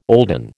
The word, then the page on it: olden: American English